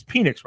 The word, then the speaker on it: Penix: It's Penix, right? Am I saying that right? – Penix. Penix. Penix.